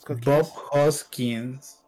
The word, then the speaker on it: Bob Hoskins: Bob Hoskins creo que lo estoy pronunciando bien, no, en estos momentos no puedo. Sí, está bien.